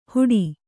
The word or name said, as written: ಹುಡಿ